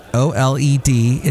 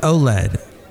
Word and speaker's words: OLED: And that's how they pronounce it. OLED, it's pronounced OLED.